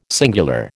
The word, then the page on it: singular: American English